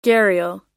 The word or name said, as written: gharial